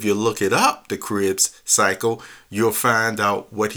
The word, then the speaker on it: Krebs: But they were all involved where he said the oxidation cascade is called the Krebs cycle. And that's spelled K-R-E-B-S. K-R-E-B-S, the Krebs cycle.